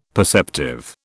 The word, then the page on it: perceptive: British English